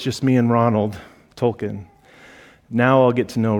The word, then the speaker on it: Tolkien: And a part of that group is a guy named Charles Williams, another guy named J.R.R. Tolkien. Let me say that right, because that's important.